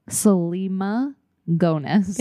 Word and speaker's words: Selena Gomez: Selena Gomez.